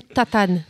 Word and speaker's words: Tatanne: José Tatam. Et je dirais même Tatane, pour ceux qui sont portugais.